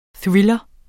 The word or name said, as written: THRILLEREN